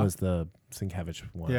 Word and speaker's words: Sienkiewicz: I never say Sienkiewicz. – Sienkiewicz. Thank you. I always pronounce that wrong.